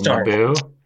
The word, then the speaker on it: Naboo: I feel like you have to emphasize the second syllable, Naboo.